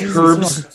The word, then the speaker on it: herb: like it, it, always weirds me out when people pronounce the H in herb. – Do that. – Seven herbs and spices, but they say H. It's like people who say the L in, sal- in salmon.